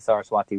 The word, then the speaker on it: Saraswati: So at the Ganges, Yamuna, and Saraswati rivers. And by the way, it took me forever to pronounce that correctly. But I finally got it.